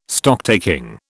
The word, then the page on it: Stocktaking: British English